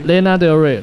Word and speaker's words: Lana Del Rey: 呃，对，好，接下来你要介绍这首叫做那个，他哎，这应该怎么念？Lana Del Rey 是不是？ – 对对，Lana Del Rey。 – Lana Del Rey，然后是一首叫做 Video Games 的歌，对啊，你你要分享一下，就是和他的故事嘛，还有你怎么会找到这首歌？